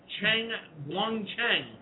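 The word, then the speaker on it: Chen Guangcheng: That is the situation dealing with the Chinese human rights dissident, Cheng Guangcheng. – Very good pronunciation.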